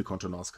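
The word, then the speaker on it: Contenance: Und äh, man kann tatsächlich sagen, bei Kyoto Animation wurde sehr viel Kontenance gezeigt. Ich hoffe, ich habe das jetzt richtig ausgesprochen. – Ja, das hast du richtig oh, ausgesprochen.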